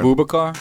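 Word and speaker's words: Aboubakar: Vincent Abakara. I don't know. He's on camera. – Abubakar? – Yeah, Abubakar.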